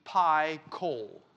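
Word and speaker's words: Phichol: The actual Hebrew pronunciation of this word is pie coal, like a pie of coal.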